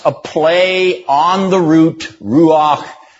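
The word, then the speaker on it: ruach (רוח): Remember, you read Hebrew from right to left, and you pronounce that Hebrew phrase, a Hebrew term, ruach. Ruach, with a guttural ch on the end of it. Okay, it even sounds breathy, doesn't it? Ruach.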